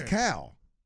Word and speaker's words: Cal: Hey, how do you say calories? – I'm saying Cal. – How do you say calories? – Calories. – Right. See, that's. – Cal. – I'm with Cal.